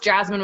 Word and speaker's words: Jasmine: And it's funny because I'll be like, oh, it's Jasmine with no E.